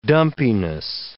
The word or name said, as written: dumpiness